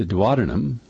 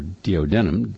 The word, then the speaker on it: duodenum: That little section, it is the duodenum, or duodenum, depending upon how you want to pronounce it, but it's spelled the same, D-U-O-D-E-N-U-M.